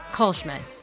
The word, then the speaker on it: Kallschmidt: Kallschmidt.